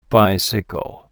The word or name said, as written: bicycle